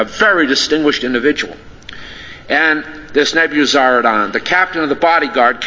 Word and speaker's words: Nebuzaradan: in the our phonetic system, it would be Nebuzaradan, but over in that country, the accent is always on the last syllable, so I imagine, even though he was a Chaldean, it was probably Nebuzaradan, but you want to notice this fellow, whether you call him Nebuzaradan or Nebuzaradan.